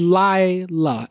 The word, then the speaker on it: Leila: Now, it's Leila, L-I-L-A is how it's pronounced, but it's spelled L-E-I-L-A. – I know. – People butcher this constantly, right? – Just think not the Eric Clapton version. – Not Leila. – Yes. – You're right. – Think Leila Garrity from Friday Night Lights.